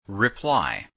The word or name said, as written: reply